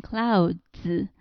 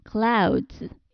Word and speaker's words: clouds: 而它变成复数以后，这个读音变成 clouds, 因为 d 和 s 这两个音合在一起会变成 z, clouds。